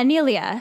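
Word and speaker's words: Neilia: It's, yeah, Nelia. Because it's like Neil and then Ia. Mm-hmm. Nelia Hunter. – I kept wanting to say Nelia. But I think it's Nelia Hunter.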